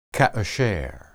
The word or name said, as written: kasher